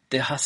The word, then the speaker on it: Texas: Entonces, Texas se escribe con X, igual que México. Pero eso no significa que se pronuncie con X en castellano, sino con J.